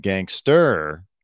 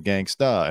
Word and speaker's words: gangsta: Nick Speicher said gangster instead of gangsta, and it cost him $3,200.